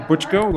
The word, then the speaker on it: Buchko: My name is Dave Butchko. – Last name is spelled B U C H K O, and I am with Lucid Motors.